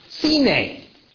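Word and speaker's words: sine (סנה): The Hebrew word for thorn bush in Exodus 3 is sine. Now, if you know Hebrew, sine sounds exactly like sine. What's the other sine? We pronounce it in English as Sinai.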